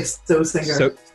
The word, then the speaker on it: Stoessinger: It's not sauce. It's sos. – No, it's supposed to be a long O. So it's like Stoessinger.